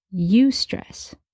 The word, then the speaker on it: eustress: There's stress as we know it, and then there's also eustress. It's spelled E-U-S-T-R-E-S-S, eustress.